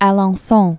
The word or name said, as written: Alencon